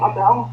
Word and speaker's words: Amell: What's his last name? Amell? – Yeah. – That's how you pronounce it? – Yep.